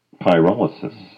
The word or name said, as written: Pyrolysis